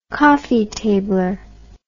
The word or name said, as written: coffeetabler